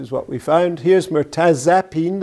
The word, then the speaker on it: mirtazapine: See, this is a weak vowel, mirtazapine. It's not a mirtazapine, it's mirtazapine, you'd pronounce it. It's pronounced weakly.